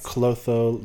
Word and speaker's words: Clotho: Clotho, Lachesis, and Atropos.